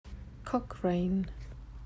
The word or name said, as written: Cochrane